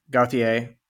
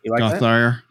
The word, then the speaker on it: Gauthier: I would have been like, Gauthier. I always pronounce the R.